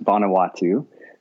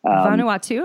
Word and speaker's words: Vanuatu: but he lived as a missionary in how do you say this? Vanuatu? – That's close. Yeah, it's pronounced Vanuatu. – Vanuatu, okay.